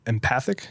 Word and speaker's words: empathic: The empathetic species. – Yeah. – Empathetic, am I saying that right? – Empathic? – Empathic? – Yeah.